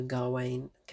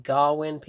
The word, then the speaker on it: Gawain: It was just kind of a lost, unknown poem about the Arthurian legend and Sir Gawain. Here's the thing, so many people pronounce his name differently. It's spelt Gawain, Gawain, but people call him Garwin, people call him Garwain, Gawain, Garwin, whatever.